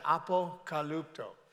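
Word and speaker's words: apokalupto (ἀποκαλύπτω): So when we look at when did God choose to reveal his son in you, the word reveal in the Greek is apokalupto. If you look at that there, that's how you pronounce that, apokalupto.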